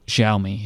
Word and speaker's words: Xiaomi: Xiaomi, yeah. Someone once told me, because I kept saying it wrong, and they said it's kind of like saying shower me.